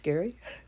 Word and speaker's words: Gerry: Now, I can't wait to share this with my mate, with Gary, G-E-R-R-Y, pronounced Gary.